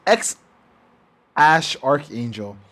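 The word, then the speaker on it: X Æ A-12: I can only say how it sounds. It sounds X. Ash Archangel, but it's actually spelled X A E letter combined A-12.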